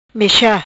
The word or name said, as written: Mecha